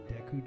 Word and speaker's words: Deku: And I didn't know some of the shit, how to pronounce some of the words, like the Deku tree. I always called it the Duke tree.